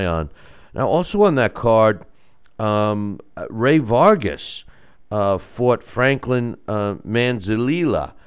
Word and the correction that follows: Manzanilla: and they were saying I could pronounce. I don't know why I can't this morning. Last night I was pronouncing it fine. Manzanilla, but anyway.